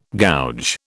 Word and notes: gouge: British English

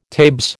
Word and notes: tabes: American English

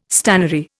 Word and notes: stannary: Indian

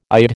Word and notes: ide: American English

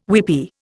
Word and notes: weepie: Indian

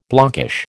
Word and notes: blockish: American English

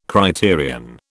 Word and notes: criterion: British English

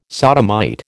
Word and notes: sodomite: American English

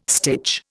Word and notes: stitch: Indian

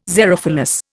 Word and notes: xerophilous: Indian

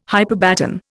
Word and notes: hyperbaton: Indian

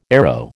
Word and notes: arrow: American English

